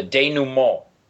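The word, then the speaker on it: dénouement: and I'm going to mess up the French pronunciation of this word, the denouement. – No, you got it right.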